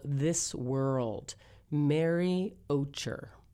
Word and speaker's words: Ocher: Let's hear from Mary Ocher. Ocher, actually. I looked this up how to pronounce it.